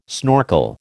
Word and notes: snorkel: American English

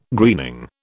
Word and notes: greening: British English

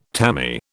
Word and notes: tammy: British English

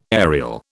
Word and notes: aerial: British English

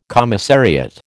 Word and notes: commissariat: American English

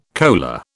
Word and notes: kola: British English

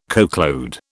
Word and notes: coachload: British English

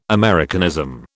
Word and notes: Americanism: British English

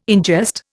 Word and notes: ingest: Indian

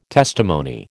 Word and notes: testimony: American English

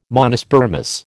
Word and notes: Monospermous: American English

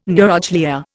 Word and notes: neuroglia: Indian

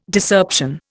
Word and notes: Discerption: Indian

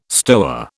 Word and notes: stoa: British English

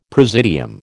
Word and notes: presidium: American English

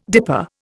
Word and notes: dipper: Indian

